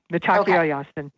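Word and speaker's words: Mitákuye Oyás'iŋ: And he would talk about Metakwayasi, all our relations. I hope I'm pronouncing that properly. – You did fine. Metakwayasin, yeah. – Thank you. I knew it was slightly different.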